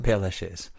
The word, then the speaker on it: Père Lachaise: Père Lachaise.